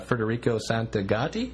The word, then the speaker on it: Federico Santagati: And hope I say his name right and don't look like a jerk, but we're here with artist extraordinaire Federico Santagati. – That's perfect.